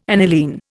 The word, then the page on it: aniline: Indian